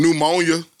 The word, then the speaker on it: pneumonia: Pneumonia. Start with a P.